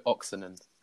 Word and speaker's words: Oksanen: One of our listeners from Finland, Mark from Finland, has been in contact with the correct pronunciation of Oksanen. So, thank you, Mark from Finland. It's Jaakko Oksanen.